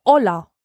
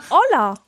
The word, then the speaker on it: Ola: Und ich heiße, frag mich nicht, wie es dazu gekommen ist, aber ich heiße in Polen Ola. – Oida. – Nein, Ola. – Ola. – Ola, so wie das spanische Ola, also das Hallo, das spanische Hallo. Es wird genauso ausgesprochen wie das spanische Hallo, Ola.